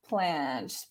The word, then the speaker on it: Planche: Planche.